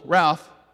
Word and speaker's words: Ralph: My name normally has one syllable, Ralph, but when she said it, it has at least two syllables, and it was Ralph.